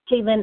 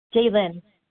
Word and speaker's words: Jalynne: Jaylen S. from Idaho. – What was the first name? – Jaylen. – Could you spell that for me, please? – J-A-L-Y-N-N-E.